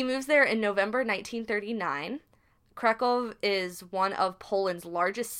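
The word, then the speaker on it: Kraków: Krakow. – In what? – Yeah. Krakow.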